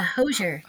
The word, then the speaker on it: Hozier: How do you say his name? – Hozier. Yeah, Hozier. – Hozier. Mm-hmm.